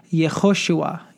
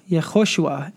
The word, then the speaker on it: Yehoshua: And today is a simple word that you may be familiar with, and is the word Yehoshua. Yehoshua. It is the word for Jesus in Hebrew.